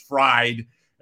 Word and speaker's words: Fried: By the way, I think they're pronouncing his name wrong. It's Fried.